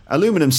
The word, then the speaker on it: aluminum: And um, that's because between American English and British English, they s- pronounce the words differently. So in Canada or in America, they'd say aluminum, and in, um, in Britain, we'd say aluminium.